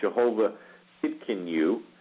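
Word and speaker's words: Jehovah Tsidkenu: Jehovah Tidkenu, kid reminds rhymes with kid.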